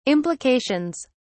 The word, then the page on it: implications: British English